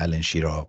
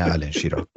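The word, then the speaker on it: Alan Shearer: چون تو همون جام جهانی 98 اگه اشتباه نکنم ما با واجه های مختلفی روبرو شدیم الن شیرر الن شیرر و و کلی تلفظ های مختلف حالا بالاخره درستش هم از شما شنیدیم خیلی خوب بله.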